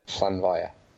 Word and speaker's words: Llanfair: Llanfair. L-L-A-N-F-A-I-R.